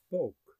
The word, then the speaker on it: spook: It comes from the Dutch word spook, spelt the same as it is in English, but it's pronounced more like this. – Spoke.